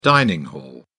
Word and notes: dining-hall: British English

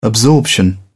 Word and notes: absorption: British English

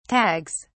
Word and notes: tags: British English

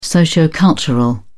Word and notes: sociocultural: British English

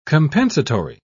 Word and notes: compensatory: British English

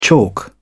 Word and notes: chalk: British English

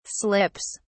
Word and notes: slips: British English

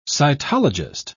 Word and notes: cytologist: English; British English

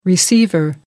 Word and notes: receiver: American English

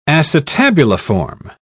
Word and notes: acetabuliform: British English

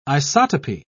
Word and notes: isotopy: British English

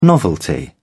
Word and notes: novelty: British English

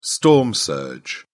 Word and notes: storm surge: British English

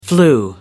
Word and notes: flu: American English

